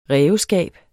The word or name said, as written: ræveskab